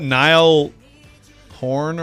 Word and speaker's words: Niall Horan: Is that Niall Horan or whatever? – One Direction.